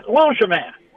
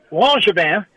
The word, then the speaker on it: Langevin: I said, down here in South Louisiana, where we got a lot of French people, we would call that Langevin. And he looked at me and he said, well, actually, that's how you say it. So the answer to your question is Langevin.